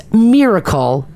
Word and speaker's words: Myrkl: It's pronounced Miracle, but it's spelled M-Y-R-K-L.